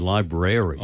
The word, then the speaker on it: library: The one thing you cannot do, which is banned absolutely and is verboten, is using the American pronunciation, which is library. – Oh, no. – You don't do rary. There's no rary in the middle of it.